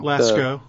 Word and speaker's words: Lascaux: Lascaux.